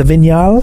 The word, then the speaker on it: Vignale: The Vignale. That's how I was saying it wrong on holiday.